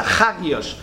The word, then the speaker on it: Hagios: En el Nuevo Testamento es la palabra Hagios. Digo ha porque en el griego la H se pronuncia, Hagios.